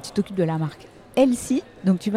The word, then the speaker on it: Elsy: Euh, donc je suis Claire, je suis la présidente de Elsie. Ça s'écrit E L S Y. Et vous l'aurez compris, c'est un jeu de mots avec l'anglais Elsie ».